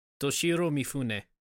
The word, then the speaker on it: Toshiro Mifune: Toshiro Mifune.